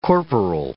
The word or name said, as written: corporal